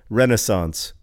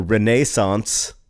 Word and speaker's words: Renaissance: And so I looked on How Jay Say, how you pronounce Renaissance, and he says Renaissance or Renaissance. And ever since then, whenever I hear the word Renaissance, I insist on like saying, you know, Renaissance is also an acceptable pronunciation.